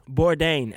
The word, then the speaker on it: Bourdain: Did I say it right? Bourdain? Yes. Bourdain.